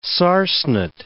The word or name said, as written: sarcenet